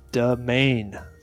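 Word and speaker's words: demesne: Okay, it's a crazy word. It's pronounced domain. – Now you know. – No, there's no S or anything.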